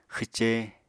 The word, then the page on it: xwce: Apatani